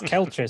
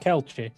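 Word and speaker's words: Kielce: Yes, to the lower town of Kiox. God, I'm murdering this. – It's Kelchi, sir. – Say again? – I believe it's pronounced Kelchi. – Yeah, like the sausage.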